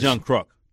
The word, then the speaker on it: Kruk: Is it John Kruk or is it Kruk? – Kruk. He's not a crook. John Kruk. Oh, maybe I've been saying it wrong for 30 years. – It's John Kruk.